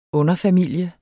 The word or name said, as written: underfamilie